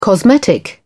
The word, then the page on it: cosmetic: British English